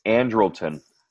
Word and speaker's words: Andrelton: Simmons is, you know, how do you say his first name? Andrelton? – Andrelton.